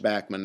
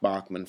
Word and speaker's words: Bachman: And yes, it is pronounced Bachman, not Bachman, for all of you guys and gals out there who thought it was Bachman.